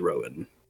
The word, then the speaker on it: Rohan: It is spelled as in the Riders of Rohan, but it is Rowan.